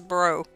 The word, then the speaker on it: Breaux: And he's from Louisiana, so that's B-R-E-A-U-X. – Gotcha. – And I looked up the pronunciation for it, and it says it's bro. – Like, what up, bro? – That's what it says.